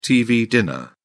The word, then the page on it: tv dinner: British English